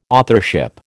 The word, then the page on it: authorship: American English